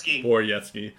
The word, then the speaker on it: Borowiecki: I always mispronounce that. – Borowiecki.